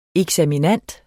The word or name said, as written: eksaminand